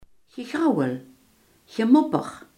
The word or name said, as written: gemopper